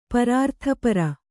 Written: ಪರಾರ್ಥಪರ

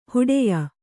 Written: ಹೂಡೆಯ

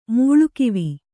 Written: ಮೂಳುಕಿವಿ

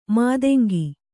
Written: ಮತಂಗಿ

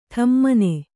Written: ಢಮಣ

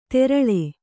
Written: ತೆರಳೆ

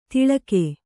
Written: ತಿಳಕೆ

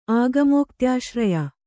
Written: ಆಗಮೋಕ್ತ್ಯಾಶ್ರಯ

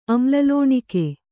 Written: ಅಮ್ಲಲೋಣಿಕೆ

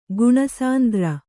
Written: ಗುಣಸಾಂದ್ರ

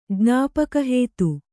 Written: ಜ್ಞಾಪಕಹೇತು

